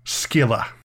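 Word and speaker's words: Scylla: God damn it, I pronounced that wrong. Skylar.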